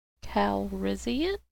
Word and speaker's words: Calrissian: Calrissian? Did I pronounce that right? – Yes, you did.